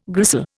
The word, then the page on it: gristle: Indian